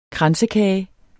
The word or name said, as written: kransekage